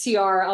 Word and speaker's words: Ciara: Is this Ciara or Kiara? – I don't know. I'm not very good at pronouncing. – I've been terrible with that. Maybe we edit this part out and I say Ciara. I think it's Ciara. It was Ciara.